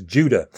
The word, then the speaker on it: Judah: The correct pronunciation of his name in Greek and Hebrew, the correct transcription into English, is Judah.